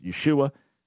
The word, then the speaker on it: Yeshua: His name would have been pronounced Yeshua.